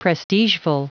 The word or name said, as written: prestigeful